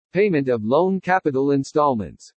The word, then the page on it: PAYMENT OF LOAN CAPITAL INSTALLMENTS: English